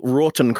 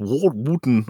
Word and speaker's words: Wroughton: Daniel Rorton Craig, CMG. Born this... – Wait. Rorton? How are you spelling that? – W-R-O-U-G-H-T-O-N. It's probably pronounced Wharton or something.